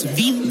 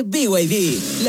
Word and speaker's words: BYD: ¿Los VIV? – ¿VIV? ¿No es VID? – Nada que ver. Se escribe BID, pero se dice BYD.